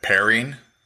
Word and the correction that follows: Perine: Perine. Yep. – those of you listening to me know I can't pronounce anybody's name. So Perine.